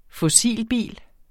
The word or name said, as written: fossilbil